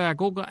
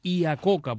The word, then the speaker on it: Iacocca: Iacocca, se pronuncia Iacocca en inglés, pero realmente es Iacocca porque el apellido es italiano, por tanto está pronunciado en inglés.